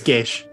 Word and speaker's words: Gaes: G-A-E-S. – Oh, yes. Geos. – It's Geish. That's how it's pronounced. – Geish?